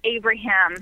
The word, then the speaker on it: Abraham: Is it Abraham or Abram? – It's Abraham. – Abraham. – Like Lincoln.